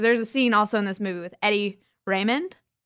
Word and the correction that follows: Redmayne: Redmayne. – I never pronounce his last name right.